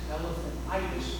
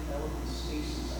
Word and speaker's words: elephantiasis: So I've heard people pronounce it elephantitis, but it's really elephantiasis, I think is how it's referred to.